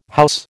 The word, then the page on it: house: American English